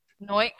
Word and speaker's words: Noic: Noik Nico. N-O-I-C Nico. If you guys don't know how to spell it.